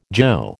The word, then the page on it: jo: American English